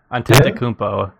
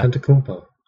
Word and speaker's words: Antetokounmpo: Michael Carter-Williams, Chris Middleton, Giannis Antetokounmpo, or however you pronounce his last name, still not 100%. – I think you got it, actually. Antetokounmpo. Yeah. – Antetokounmpo.